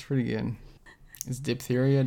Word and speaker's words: diphtheria: Died in infancy from diphtheria. How do you say that? – Diphtheria? – It is diphtheria? – Yeah.